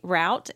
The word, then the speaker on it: route: And I remember people would always say route, and I didn't know what they meant because I was a kid and I had only heard it pronounced as route.